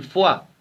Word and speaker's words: foie: El Español es, como la palabra de moda en muchos restaurantes modernos, el foie, que se escribe con una O, una E y una I, y se pronuncia con una U y una A.